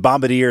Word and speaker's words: Bombardier: Bombardier, which Boeing is based out of Seattle, and they've got Chicago offices as well. It's the America's largest airplane manufacturing company, and Bombardier is out of Quebec, Canada.